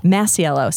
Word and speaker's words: Masiello: Masiello.